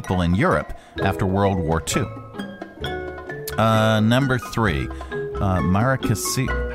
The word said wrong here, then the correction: Maracaibo: Maracaibo. – Maracaibo. That's the lake, right? – Maracaibo.